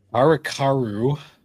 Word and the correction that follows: Harukanaru Densetsu: No, Harukanaru Densetsu.